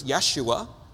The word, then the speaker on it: Yeshua: In Hebrew, it's Yeshua.